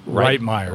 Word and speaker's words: Reitmeier: So it's Tyler Reitmeier.